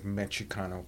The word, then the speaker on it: Mexicano: I mean, anybody can tell you that the word Chicano comes from the word Mexicano, which is a Nahuatl word. The X is pronounced like a CH,